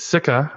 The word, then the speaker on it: Sikka: So the next story is Sika. I really hope I'm saying that right. – You totally. That sounds right to me.